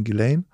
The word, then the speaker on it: Ghislaine: Und jetzt ist es Zeit, Ghislaine Maxwell vorzustellen. Das ist die, von der du vorher geredet hast, dass deine Frau ist. Der Name ist sehr interessant geschrieben, wird ausgesprochen Ghislaine.